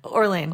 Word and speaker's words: Orlean: Does she pronounce her last name Orlean or yeah. – Orlean? – Orlean.